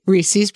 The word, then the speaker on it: Reese's: Reese's peanut butter cups. – It's Reese's. Like, it rhymes with pieces.